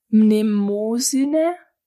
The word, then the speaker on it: Mnemosyne: Genau, vor den olympischen Göttern eben die Titanen, Kronos, Rhea, Okeanos, Hyperion, Iapetus und, kann ich nicht aussprechen, Mnemosyne.